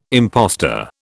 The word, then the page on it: impostor: British English